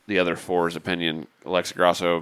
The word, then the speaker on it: Grasso: It's Grasso.